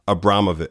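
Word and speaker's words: Abramović: Marina Abramovic. Mm-hmm. Hopefully I'm saying her name correctly. – You are.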